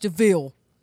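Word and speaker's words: De Vil: It's called a devil. DeVille. It's pronounced DeVille.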